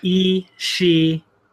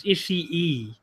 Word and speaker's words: Ishii: Ishii. You have to do two E's at the end. It's like each she is one syllable.